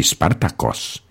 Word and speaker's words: Spartakos: A jenom jako připomínka, i nejslavnější otrok a gladiátor Spartacus, podle řecké historické výslovnosti Spartakos, známý svým povstáním, byl původem Trák, stejně jako o několik set let před ním slavný bajkař Ezop.